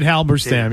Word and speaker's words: Halberstam: I love the book Making of a Coach by Halberstam, I think it's pronounced. – David Halberstam, yes.